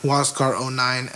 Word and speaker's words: Huascar: I'm an at Huascar09, and before, let me say my name is very difficult to spell.